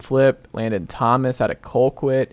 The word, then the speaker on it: Colquitt: Because that is pronounced Colquitt, sir. – It's Colquitt. – It is. That might be how it's spelled to you, but us down here folk in Georgia, we say Colquitt.